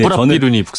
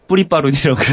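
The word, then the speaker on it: 쁘라삐룬: 네, 뿌라비룬이 북상한다. 저 뉴스에서 뿌리바룬이라고 그러니까요.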